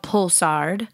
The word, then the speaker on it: Poulsard: So the first varietal is called Pulsard. It's spelled Poulsard.